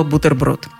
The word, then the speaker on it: бутерброд: Он даже и не заметил, как произнес иностранное слово – оно скрывается под привычной свойской маской слова «бутерброд». Во-первых, Слово произносится как «бутерброд» с твердым «т» и никак иначе.